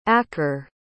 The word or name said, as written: akre